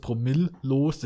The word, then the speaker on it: Promillos: Nee, äh, das spricht man deutsch aus, Promillos, das war halt ein alkoholfreies Bier.